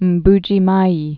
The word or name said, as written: Mbuji Mayi